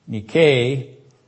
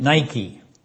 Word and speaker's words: nike: And that's related to the Greek word nike, which we translate Nike, not the proper pronunciation of it, but the Greek pronunciation is Nike.